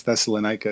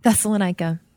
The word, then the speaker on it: Thessalonica: I think it's Thessalonica is how most Americans would say it. – Thessalonica.